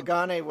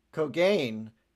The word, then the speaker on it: Kogane: Kogane was the original Japanese pronunciation. But there was this one particular episode where they used Commander Cogain in Yeah.